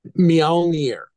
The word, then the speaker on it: Mjölnir: So the new album is Mjolnir. Is that how you pronounce it? – Mjolnir.